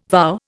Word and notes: vow: Indian

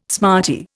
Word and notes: smarty: Indian

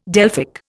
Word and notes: Delphic: Indian

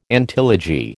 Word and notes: antilogy: American English

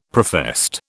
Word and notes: professed: British English

